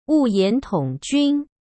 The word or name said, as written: Wuyan Tongjun